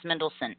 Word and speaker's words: Mendelsohn: Now, the reason why I'm actually excited to see Orson Krennic in this movie, who's played by Ben, I'm gonna mess up his fracking name, Mendelsohn. Me- Mendelsohn, I think it's called, it's pronounced Mendelsohn.